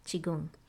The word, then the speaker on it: Qigong: is the practice of Qigong.